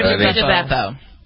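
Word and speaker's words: Buca di Beppo: Bocca di Beppo.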